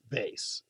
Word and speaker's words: vase: Webster says that the number two pronunciation, as in vase, as like haze, is heard usually in Canada.